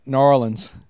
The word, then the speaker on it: New Orleans: Yeah, and down in New Orleans, they pronounce it Narlins.